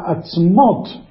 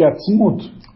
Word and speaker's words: atzmot: Só que em hebraico a palavra atzmot se escreve igual que atzmut. E se pronuncia quase igual. Atzmot são ossada. Atzmut é essência.